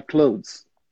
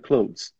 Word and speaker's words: clothes: Você vai pronunciar assim. My clothes. Pronto. – My clothes. – Pronto. My clothes.